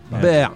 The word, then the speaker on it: Bear: Je prononce peut-être mal, c'est B-I-R. – B-I-R. – Ber. B-E-A-R. B-E-A-R. Ce qui veut dire ours. Ouais. Ah ouais, ber.